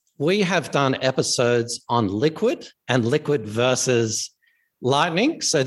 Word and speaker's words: LQWD: So, and our Liquid is spelled LQWD, the same as our Toronto Stock Exchange ticker. And so a lot of people just call it, they call it, hey, LQWD, we call it Liquid.